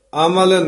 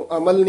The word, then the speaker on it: अमाल: अमल न अमल न मलन शाह नहीं धूप, पाठ बड़े ध्यान नाल उच्चारण करना है, इस नु अमल नहीं कहना, अमाल बोलना है,